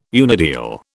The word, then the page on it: unideal: British English